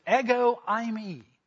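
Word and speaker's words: ego eimi (ἐγώ εἰμι): But I'll tell you how it's pronounced. It's ego eimi.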